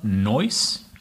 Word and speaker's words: Noyce: Directed by Philip Noyce. – Noyce. – I think that's how it's pronounced. It's N-O-Y-C-E. So it's like Joyce, but with an N. So Noyce.